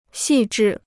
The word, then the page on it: 细致: xì zhì